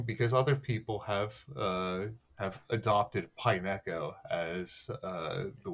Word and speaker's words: Pineco: It is pronounced Pineco.